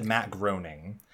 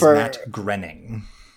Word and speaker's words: Groening: So first of all, I'm going to say right now, neither of you have said his name correctly. Brian said Matt Groening. Paul said Matt Groening, which is hilarious, actually. The man's name is Matt Groening.